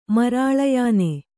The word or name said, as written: ಮರಾಳಯಾನೆ